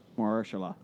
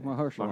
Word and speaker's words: Mahershala: Mahershala. – Thank you. – Yeah, okay, Mahershala. Mahershala Ali.